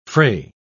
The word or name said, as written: frae